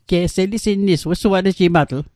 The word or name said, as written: wesuatejimatl